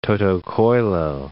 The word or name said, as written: toto coelo